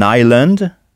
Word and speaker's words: island: Sachant que une île se dit an island, ça s'écrit I-S-L-A-N-D, mais on ne prononce pas le S.